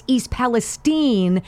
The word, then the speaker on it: East Palestine: The reason why Joe Biden doesn't know that it's East Palestine